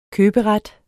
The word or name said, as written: køberet